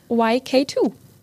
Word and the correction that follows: Y2K: Und ich habe es, glaube ich, gerade falsch gesagt, es ist Y2K, so heißt es richtig.